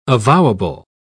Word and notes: avowable: British English

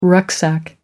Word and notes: rucksack: American English

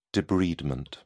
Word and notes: debridement: British English

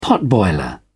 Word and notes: potboiler: British English

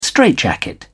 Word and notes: straitjacket: British English

